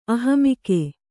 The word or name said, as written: ಅಹಮಿಕೆ